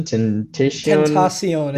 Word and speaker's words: Tentacion: Is it Tentacion? – Tentacion. It's supposed to be like Spanish, yeah.